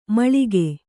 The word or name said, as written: ಮೇಲಕ್ಕ